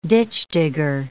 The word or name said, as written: ditchdigger